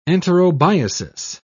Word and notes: enterobiasis: British English

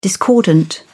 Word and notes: discordant: British English

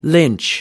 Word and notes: lynch: American English

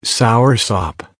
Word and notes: soursop: American English

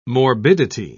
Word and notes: morbidity: British English

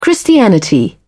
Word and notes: christianity: British English

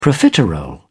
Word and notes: profiterole: British English

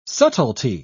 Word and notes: subtilty: British English

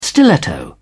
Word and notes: stiletto: British English

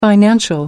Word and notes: financial: American English

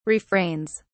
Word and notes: refrains: British English

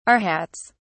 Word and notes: arhats: British English

